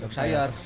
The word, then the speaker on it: Yorkshire: Yorkshire atau eh. Yorkshire bacanya? Yorkshire.